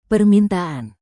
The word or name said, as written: permintaan